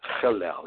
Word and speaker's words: Hillel: The first rabbinical school was the school of Hillel, H-I-L-L-E-L, and that's Hillel. It's pronounced, that's a guttural, Hillel.